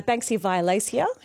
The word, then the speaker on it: Banksia violacea: my Banksia violacea, is that how you say it? – Yep.